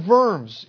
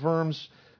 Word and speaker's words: Worms: Worms is W-O-R-M-S. Worms, I believe, is how it's pronounced. Sounds better than worms.